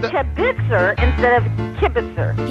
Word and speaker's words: kibitzer: Would you please say the word kibitzer with the emphasis on the kib instead of the kibitzer? – Oh, Jose, shalom, bimromav.